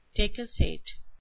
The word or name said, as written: decussate